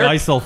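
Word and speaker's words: Eisele: And by the way, I always mispronounce it. Icellars. – It's the Icel family.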